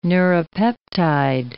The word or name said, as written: neuropeptide